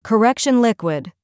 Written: correction liquid

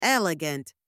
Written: elegant

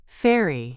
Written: ferry

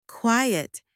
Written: quiet

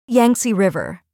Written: Yangtze River

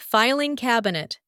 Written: filing cabinet

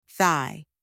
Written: thigh

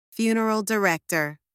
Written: funeral director

